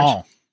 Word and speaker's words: Poisson: How do you say Poisson. – It? – Poisson.